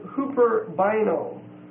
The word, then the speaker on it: huperbaino: The next word is the word huperbaino. H-U-P-E-R-B-A-I-N-O. Long O. Huperbaino. This means to overreach between mine and yours. H-U-P-E-R. B-A-I-N-O.